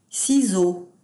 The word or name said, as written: ciseaux